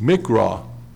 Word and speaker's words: miqra: The word convocation is the Hebrew word M-I-Q-R-A, pronounced mikra, which is number 4744 in Strong's Concordance.